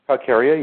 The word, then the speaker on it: Calcarea: And the two that do not need silica soil, but actually calcareous soil, is that how you pronounce it in English? Calcarea? – Calcarea, yeah.